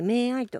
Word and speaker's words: MAYII: はい、アルファベットで MAYII と書いて名愛と言います。